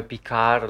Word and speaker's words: Picard: Eh, poi sarebbe Picard, la D non si dovrebbe pronunciare perché è francese, a me francese fa cacare, quindi lo pronuncio come voglio.